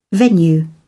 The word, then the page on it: venue: British English